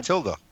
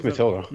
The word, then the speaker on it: Mathilda: Mathilda? – What? Mathilda. – Yeah, it's just Mathilda.